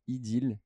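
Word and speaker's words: Idéel: Tu peux rappeler le nom de ta startup Ideal. Alors attention, ça s'écrit I-D-E-E-L.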